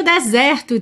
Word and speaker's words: desert: D-E-S-E-R-T, D-E-S-E-R-T. Mas a sílaba tônica é a primeira e esse E é aberto. Então a gente fala desert.